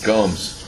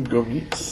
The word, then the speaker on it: Gomes: Gomes. – We've always pronounced him Gomez. – Well, it's because you say it wrong.